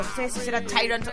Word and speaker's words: Tyrant: Tyrant o Tyrant. La pronunciaré bien. La nueva es Tyrant.